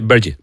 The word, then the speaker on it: Burks: Pero no Alonso como lo pronunciamos, sí. Él dice Bergi.